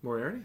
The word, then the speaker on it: Moriarty: Is it Moriarty? There's no way that's her last name. – I think so. – Is that how you say it? – Moriarty, yeah.